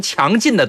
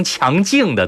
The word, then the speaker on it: 强劲: 突然想起了，比如说你是一名强劲的对手，错，你是一名强劲的对手。